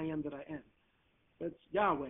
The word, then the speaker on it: Yahweh: So it's not Jehovah. It's the closest, it's YHWH. We don't know how it was pronounced. But Jehovah? It's not. It's pronounced Yahweh. That's the correct pronunciation.